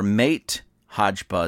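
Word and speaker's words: Máté Hajba: And this is by a gentleman by the name of Matt Hodgba, or Mate Hodgba is the way it is spelled. M-A-T-E, first name. H-A-J-B-A, second name, or last name.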